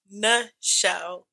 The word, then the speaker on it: Nichelle: Y'all can follow me at Real Time with Nichelle with an N-I-C-H-E-L-L. – Is that another way to spell it? – Yeah, because people call me Michelle.